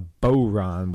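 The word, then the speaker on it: bodhrán: You have what's called a boron, when the D is silent, I think.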